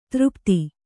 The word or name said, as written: ಧಾರಾಪಾತ